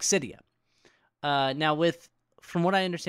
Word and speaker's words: Cydia: They're getting sued by, how do you say it? Cydia? – Yeah, it's Cydia.